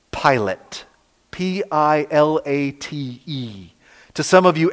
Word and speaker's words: Pilate: But traditionally, we pronounce this name Pilate, kind of like the guy in the front of the airplane.